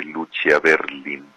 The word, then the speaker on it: Lucia Berlin: En este espacio ya hablamos de Lucia Berlin. Se escribe Lucía Berlín, pero sin acentos, ni Lucía ni Berlín.